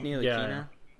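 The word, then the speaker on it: Ntilikina: They got a couple of good key pieces like RJ and Robinson and Knox and Neela Kina or whatever. I think is how it's pronounced. – Yeah, it's Frank Neela Kina.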